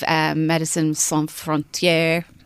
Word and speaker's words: Médecins Sans Frontières: He's a humanitarian, so he flies over with the likes of Médecins Sans Frontières.